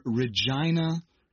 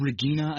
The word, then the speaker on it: Regina: Anyways, this person is writing with greetings from Regina, Saskatchewan in Canada. – Which I had mispronounced. – You said Regina, I think, and I was so embarrassed because I knew the correct pronunciation, and I shut my yapper.